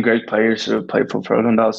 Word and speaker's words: Frölunda: Yeah, so that franchise, for people who don't know, Frölunda, is that correct pronunciation? – Yeah.